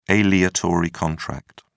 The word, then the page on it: aleatory contract: British English